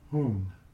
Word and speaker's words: Hung: It's Om Ah Hung. – H-U-N-G? – H-U-N-G.